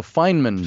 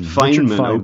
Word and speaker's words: Feynman: And you've got a nice quote towards the end of the book from, I'm not sure how I pronounce his name, the physicist, is it Richard Feynman? – Feynman. – Feynman, okay.